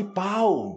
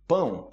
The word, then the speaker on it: pão: Cuidado com a pronúncia de pão, ok? Não fale pau, porque você já sabe que pau pode ser, né?